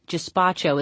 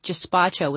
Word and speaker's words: gazpacho: For the first 20 minutes we ate in silence, with the exception of the dry cleaner remarking this gazpacho is heavenly. He pronounced gazpacho with a soft G. Gazpacho. Not a hard G, the way it should be pronounced.